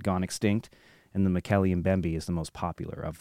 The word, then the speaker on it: Mokele-Mbembe: Dr. Roy Mackle, he says it's pronounced Mokele-Mbembe.